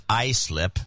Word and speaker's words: Islip: By the way, that Islip town is pronounced Islip. – Yeah, Islip. Yeah, I should have known a lot better.